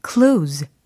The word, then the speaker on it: close: 이 close 는 철자가 close 잖아요 근데 이게 상황에 따라서 발음이 두 가지로 나뉠 수가 있어요. 오늘은 close, close 이렇게 들으셨죠. 근데 어 예를 들어서 카펜터즈의 그 close to you 이거 할 때, 예, 그때는 close 이렇게 발음이 됩니다. 아, 어떤 차이가 있느냐. close 라고 하면 동사적인 의미고요. close, close 라고 하면 형용사적인 어, 의미다 라고 해석하시면 됩니다.